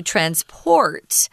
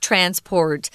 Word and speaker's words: transport: So um, if you want to be really accurate, you'll say transport with a heavier or stronger symbol on port. Um, and then the noun is just transport and it flips. The stronger syllable is on trans.